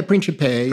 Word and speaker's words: Principe: I think, is it Father Principe? Is that how you say it? – Yeah, Principe. Principe. Yes, it means, This is a young local priest who you've said would take kids to the movies, I think.